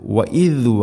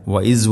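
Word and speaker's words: وإذ: വഇദ് വഅദ്ന ഇവിടെ എല്ലാ ക്ലാസ്സിലും പറയാറുള്ളത് പോലെ തന്നെ ദാലിൻ്റെ ഉച്ചാരണം ദ എന്നോ ദ എന്നോ ആവരുത്. വഇദ് വഅദ്ന, വഇസ് വഅദ്ന അല്ല വഇദ് വഅദ്ന.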